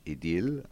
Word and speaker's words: Idylle: Number 6, which is an idyll, depending on how you prefer to pronounce it, or if you want the French, Idylle, as I think how that's pronounced.